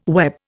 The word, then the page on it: web: Indian